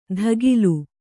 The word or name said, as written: ಧಗಿಲು